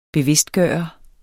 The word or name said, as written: bevidstgøre